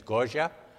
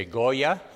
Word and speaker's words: Goya: The correct pronunciation in Argentina is Goya. You would say Goya. It's G-O-Y-A.